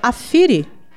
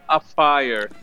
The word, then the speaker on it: Afire: É Afiri? – Poderia até ser, mas esse é o título norte-americano do filme que, por alguma razão, foi mantido no Brasil. Então, a pronúncia correta seria Afire.